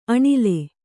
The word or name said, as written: ಅಣಿಲ್